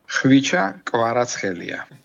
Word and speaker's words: Khvicha Kvaratskhelia: Khvicha Kvaratskhelia.